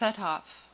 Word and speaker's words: Suthoff: And then I have down to add a little more about the bell, where it is now, and you know, and then is it pronounced a suffix house? Is that? – It's Suthoff. S-U-T-H-O-F-F.